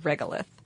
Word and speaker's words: regolith: So the dust that covers Mars is called regolith. – Mm-hmm. – If I am pronouncing that correctly. – You are.